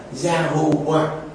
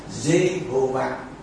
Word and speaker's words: Jehovah: Thôi, bây giờ mình đừng đặt, mình đừng dù ghép lại như vậy, nhưng không đọc là Gia Hô Văn, mà đọc là Jehovah.